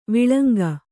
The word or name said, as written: ವಿಳಂಗ